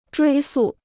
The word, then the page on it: 追溯: zhuī sù